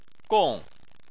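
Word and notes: gong4: tone 4

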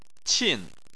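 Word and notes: qin: tone 4

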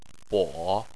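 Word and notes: bo: tone 3

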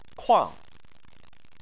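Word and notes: kuang: tone 4

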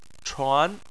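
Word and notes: chuan2: tone 2

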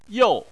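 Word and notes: you: tone 4

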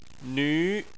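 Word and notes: nv2: tone 2

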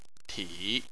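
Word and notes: ti: tone 3

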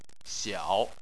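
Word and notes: xiao: tone 3

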